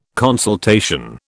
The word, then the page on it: consultation: British English